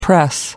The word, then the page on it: press: American English